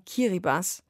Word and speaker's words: Kiribati: Ausgesprochen Kiribati.